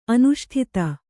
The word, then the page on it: ಅನಿಷ್ಟತೆ: aniṣṭate